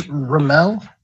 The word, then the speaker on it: Romall: It's Romall. It's German, but it was a lot of fun.